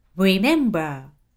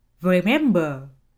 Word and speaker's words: Remember: それじゃあ、これが巻き舌の R ね。 Remember。で、次が本来の発音。 Remember。